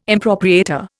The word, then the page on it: impropriator: Indian